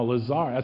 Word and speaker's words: Lazarus: Now, Lazarus, that's how it's pronounced, Lazarus, because I got familiar with this entity right off the bat when I started researching the New Age.